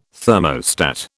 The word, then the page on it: thermostat: British English